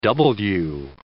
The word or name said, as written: double-u